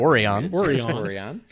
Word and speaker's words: Orion: We have one of our planets is missing, beyond the farthest star, the infinite Vulcan, pirates of Orion, or however they pronounce it. – Orion.